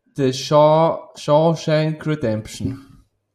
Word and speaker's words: The Shawshank Redemption: Oder der Originaltitel, habe ich gesehen, The Shawshank Redemption. – Ja, schön ausgesprochen, genau.